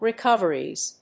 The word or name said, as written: recoveries